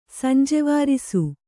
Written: ಸಂಜೆವಾರಿಸು

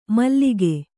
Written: ಮಲಕು

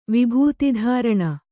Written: ವಿಭೂತಿಧಾರಣ

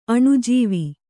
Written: ಅಣುಜೀವಿ

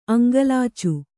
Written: ಅಂಗಲಾಚು

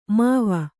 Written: ಮಾವಿ

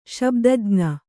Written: ಶಬ್ದಜ್ಞ

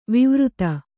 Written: ವೈವರ್ತ

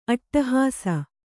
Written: ಅಟ್ಟಹಾಸ

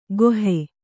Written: ಗೊಹೆ

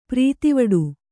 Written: ಪ್ರೀತಿವಡು

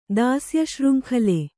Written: ದಾಸ್ಯಶೃಂಖಲೆ

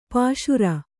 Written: ಪಾಶುರ

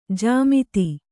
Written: ಜಾಮಾತ

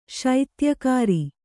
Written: ಶೈತ್ಯಕಾರಿ

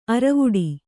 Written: ಅರವುಡಿ

